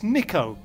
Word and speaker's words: Nico: And you're like, if you're going to pronounce properly, it's Nico, not Nika.